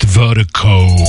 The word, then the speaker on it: Virdiko: And Vertigo is spelled V-I-R-D-I-K-O. That's V-I-R-D-I-K-O, pronounced Vertigo. It's the global music promotion, so big, big shout out Vertigo Mobile.